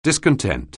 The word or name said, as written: discontent